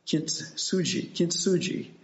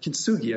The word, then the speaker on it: kintsugi: And it has to do with a Japanese art form called kintsugi. Or kintsugi, I guess it is, the hard G.